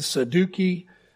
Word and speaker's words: Sadducees: A majority of the priests in those days were chosen from among the Sadducees. When you first read it, you said Saduki. You're actually probably closer to the actual pronunciation of that word.